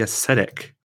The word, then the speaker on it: ascetic: Let's move on to the ascetic, which I only learned after the fact is pronounced that way. I used to pronounce the C as a hard C.